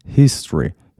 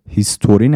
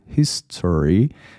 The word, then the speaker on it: history: ابتدا تلفظش هست history. هیستوری نگید. یه شوهای خیلی خفیف هست، یعنی صدای میتونید بگید history، میتونید اون رو اصلا حذفش کنید بگید history بهتره فکر میکنم. – و اما عبارت جالبی که این جمله داشت sparked my interest. spark S-P-A-R-K به معنی جرقه یا جرقه زدن.